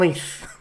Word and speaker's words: Leith: Is it Leith? Leith? How do I say it? – Oh, God almighty. I'm glad I'm here. – Yes. – Sunshine on Leith.